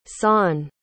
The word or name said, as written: shaan